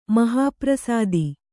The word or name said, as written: ಮಹಾಪ್ರಸಾದಿ